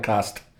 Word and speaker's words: Castres: It's spelled Castres, but they call it Cast.